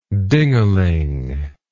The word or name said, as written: dingaling